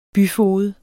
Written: byfoged

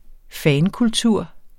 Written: fankultur

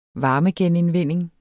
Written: varmegenindvinding

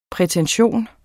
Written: prætention

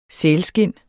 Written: sælskind

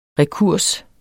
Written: rekurs

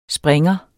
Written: springer

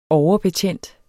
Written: overbetjent

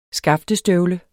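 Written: SKAFTESTØVLE

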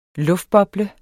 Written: luftboble

